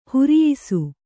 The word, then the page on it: ಹಿರಿಯಿಸು: hiriyisu